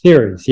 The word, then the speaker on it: Ceres: It's Ceres, right? – Ceres, yeah. – Okay, got it. Oh my gosh, it's spelled C-E-R-E-S.